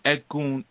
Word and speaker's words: Egun: And Egun is spelled E-G-U-N, okay?